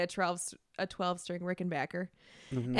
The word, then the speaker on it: Rickenbacker: I think it's the last one that Tom Petty did, but he um is talking about Rickenbacker ba- guitars, but he says, which I actually learned is it's pronounced Rickenbacker, and so like whenever that guitar is mentioned in my head, I just hear Tom Petty saying actually, Rickenbacker.